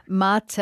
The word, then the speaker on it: mate: I was saying yerba mate. That's the cup that the Argentinians use. It's got a very interesting shape. It's pronounced mate, and you're saying that it does taste quite bitter and unpleasant.